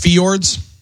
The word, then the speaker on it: fjords: It's pronounced fjords.